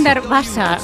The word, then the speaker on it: Hundertwasser: Me lo han corregido antes, Hundertwasser. Es Hundertwasser. – Sí. – Que sabe alemán, sí, inglés, italiano. – Sí, me ha dicho Hundertwasser.